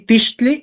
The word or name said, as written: tixtli